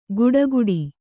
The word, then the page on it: ಗುಡಗುಡಿ: guḍaguḍi